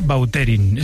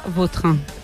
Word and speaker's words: Vautrin: Es que a mí me suena mejor Bauterín. Es como... – Da más fuerza, ¿no? – Más fuerza, sí, más pronunciación. – Sí, elegante. – La original francesa, Vautrin.